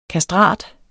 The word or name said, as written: KASTRATEN